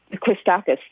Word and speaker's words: Christakis: But I agree with Dr. Christakos, sorry if I mispronounced it, that Christakis, thank you.